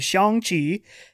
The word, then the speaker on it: Shang-Chi: Where like Asian people, like legit Chinese, are like, you know, it's Shang, right? That's how we say that name. Shang-Chi.